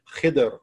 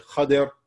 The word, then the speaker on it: Khidr: and his name was Khidr, or some people say Khadr. There's about 10 different ways you could pronounce his name according to the scholars, but we'll go with Khidr, so it'll be easier to say. Some people think it's Khadir, Anyway, that's fine.